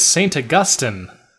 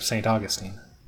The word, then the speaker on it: St. Augustine: Which is acceptable, but not the Americanized pronunciation of St. Augustine. – Right. Yes. When we're talking about the saint, I think St. Augustine is how I generally hear it pronounced, but the city is definitely St. Augustine.